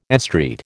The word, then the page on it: estreat: American English